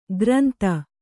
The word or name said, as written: ಕೋರಿಂದೆ